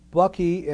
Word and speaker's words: Bucke: The third is Bucky, and it's spelled B-U-C-K-E's Cosmic Consciousness. And the fourth book is by Raynor, R-A-Y-N-O-R Johnson, and it's called Imprisoned Splendor.